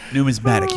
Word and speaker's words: numismatic: Oh, the mismatic humor. – Oh, my God. That was great. – Numismatic humor. I pronounced it wrong.